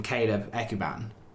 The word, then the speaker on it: Ekuban: I think there cannot be even 30 seconds between Bettinelli's exceptional save from Caleb Ekuban.